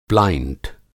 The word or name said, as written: प्लाइंट